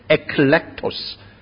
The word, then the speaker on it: eklektos: Actually, the word chosen is coming from a Greek word which can be pronounced as eklektos, which means basically it is talking about election.